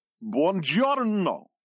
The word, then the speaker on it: buongiorno: It's pronounced buongiorno. Your mouth is getting lazy on the second syllable.